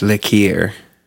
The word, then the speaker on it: liqueur: So what I heard you say was licking ears. Is that what you said? – That's what I heard you say was liqueur. – Liqueur. That's how you say it. In the original French.